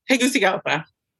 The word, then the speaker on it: Tegucigalpa: Tegucigalpa.